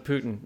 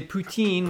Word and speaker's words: Putin: By the way, we're going to use the American pronunciation of Putin. We're not going to say Poutine, which is what the French say. And Putin is a bad word in French.